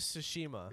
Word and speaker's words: Tsushima: The fucking pronunciation Tsushami. – Tsunami. It actually is Tsushima.